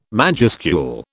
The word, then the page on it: majuscule: British English